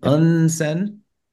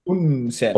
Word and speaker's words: onsen: How do you pronounce it again? Unsen? – Onsen.